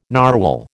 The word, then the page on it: narwhal: American English